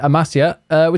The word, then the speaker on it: Amasya: Amasya, which is another city across the north of the country in the Black Sea region of Turkey.